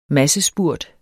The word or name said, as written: massespurt